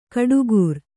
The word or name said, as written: ಗುಟ್ಟುಗಾರ